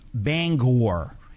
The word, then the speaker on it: Bangor: How do you pronounce Bangor? Is it Bangor, Bangor? – Bangor, Maine. – Yes. – Is pronounced Bangor, not Bangor. Bangor.